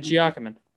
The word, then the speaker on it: Giacomin: Giacomin? Is that how you say it? – Ed Giacomin? – Yeah, Ed Giacomin.